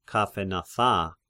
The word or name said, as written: Chaphenatha